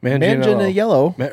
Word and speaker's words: Manganiello: Manganiello. God, no. He's a Manganiello. – It's a hard G? – Manganiello. No, it's like, it's almost...